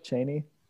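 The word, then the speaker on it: Chani: He's like, yeah, Chaney will bear me many more sons. No problem. Okay, man. – Is that how they pronounce it in the audiobook, Chaney? – Yeah.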